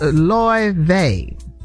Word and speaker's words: Laufey: Her name is Loy Vey. It's spelled L-A-U-F-E-Y.